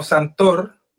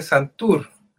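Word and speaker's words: Santoor: Mira, se escribe con doble O, así como Santor, pero se dice Santur.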